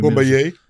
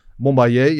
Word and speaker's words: Bombaye: maar hij gaat 28 december gaat hij, in Japan, en dan moet ik het goed uitspreken, het Inoki Bombaya. Dat is nu... – Bombaye. – Bombaye, ja, een memorial voor Inoki natuurlijk.